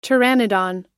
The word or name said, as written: pteranodon